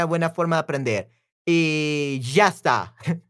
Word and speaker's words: ya está: ya, no, es ya. Ya está. This is so hard. How do you say that? So easy. Ya, eh, ya está. Okay, y ya está is that's it in Argentinian accent. Ya está. Oh, Shasta, Shasta.